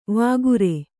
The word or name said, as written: ವಾಗುರೆ